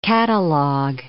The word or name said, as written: catalog